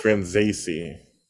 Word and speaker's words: Franzese: Am I saying that correctly? – Absolutely not. It's Franzese. – Franzese. – Zay, it's an A sound.